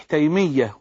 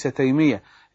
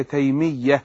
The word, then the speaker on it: تيمية: وعلى فكرة النطق الصحيح تيمية وليس تيمية، إنما بس هو اللهجة البدوية ساعات بتخلي الناس تخطف كده، إنما هي تيمية.